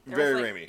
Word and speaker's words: Raimi: Raimi, There was like a bunch of Raimi in this movie.